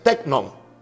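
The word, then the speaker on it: teknon (τέκνον): La palabra hijos ahí es teknon. en el griego se pronuncia más o menos así. T-E-K-N-O-N.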